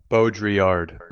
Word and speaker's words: Baudrillard: Baudrillard.